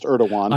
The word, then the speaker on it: Erdogan: And I don't even want to venture to try to pronounce his first and middle name, but his last name is pronounced Erdogan.